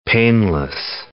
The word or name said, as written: paneless